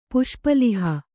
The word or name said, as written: ಪುಷ್ಪಲಿಹ